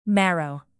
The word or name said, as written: marrow